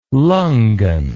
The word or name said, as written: lungan